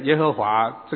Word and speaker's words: 耶和华: 那么当我们现在念耶和华这个名字的时候，实际上来说我们就是加上阿多乃的这个读音，所以把神的名字念成耶和华。